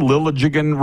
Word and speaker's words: Liljegren: And wee Willie Nylander had that assist on Liljegren. Am I saying Liljegren right? Is 400 point. – Right. – How do you say? Liljegren. – Okay.